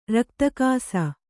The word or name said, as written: ರಕ್ತಾಕ್ಷ